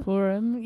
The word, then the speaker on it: Purim: Purim.